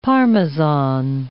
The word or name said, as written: Parmesan